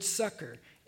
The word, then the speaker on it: succour: It's the word sucker, but it's spelled S-U-C-C-O-U-R. And it's like S-O-S, send out sucker.